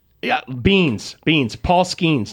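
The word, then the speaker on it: Skenes: Name rhymes with Skeens. – Yeah. Isn't it just Skeens? – I think so. What else rhymes with Paul Skeens, guys? – Leans. I was thinking leans, scenes.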